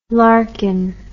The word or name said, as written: Larkin